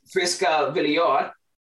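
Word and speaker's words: Friska Viljor: I believe Saturday is your first game, I believe, of the season against Friska Villior. I pronounced that right, hopefully. – Yeah, that's right.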